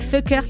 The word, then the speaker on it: Fcukers: C'est Fockers, ça s'écrit F-C-U. K-E-R-S. Ils ont mis le C au mauvais endroit et je pense que c'est fait exprès.